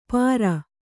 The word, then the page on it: ಪಾರ: pāra